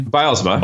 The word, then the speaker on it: Bylsma: Am I saying that correctly? – Bylsma. – Bylsma. Close. Okay.